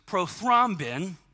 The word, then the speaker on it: prothrombin: Well, in the 1930s, a bunch of scientists discovered a blood clotting agent to heal wounds, and someone graciously helped me correct the pronunciation of this, which is prothrombin. Nailed it. In the 1930s, discovered prothrombin, which is a vitamin K kind of thing that creates blood clotting so that your wounds can heal closely.